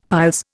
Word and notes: piles: Indian

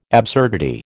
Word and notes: absurdity: American English

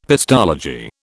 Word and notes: pestology: British English

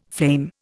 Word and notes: flame: Indian

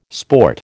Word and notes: sport: American English

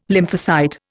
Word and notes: lymphocyte: Indian

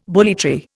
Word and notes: bully tree: Indian

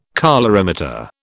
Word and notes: colorimeter: British English

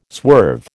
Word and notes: swerve: American English

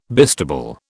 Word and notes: bistable: British English

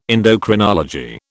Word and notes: endocrinology: British English

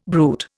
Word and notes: brute: Indian

